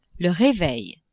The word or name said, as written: reveil